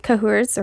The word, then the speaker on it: Cahors: So your cooler region is going to be France, or in a region called Cahors, or Cahour. I've heard the Cahor. – S. Cahor. Cahor. S is silent.